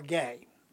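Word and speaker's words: gay (גיא): The Hebrew word for the word valley is G-A-I, or gay, G-A-Y, if we want to spell it with our I and Y sounds. The Hebrew word for Gath is Gat, G-A-T.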